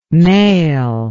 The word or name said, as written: maill